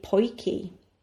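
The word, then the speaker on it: Potjie: And the thing I'm going to talk about is the poiki. Poiki, which is spelt P-O-T-J-I-E, T-J-I-E makes the sound key, is short for Potjiekos, which means small pot food in Afrikaans.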